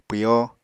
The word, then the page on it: pwyo: Apatani